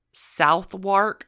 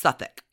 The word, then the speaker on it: Southwark: London at the time is separate, divided by the Thames from a city south on the Thames called, it's spelled Southwark, but it's pronounced Southwark.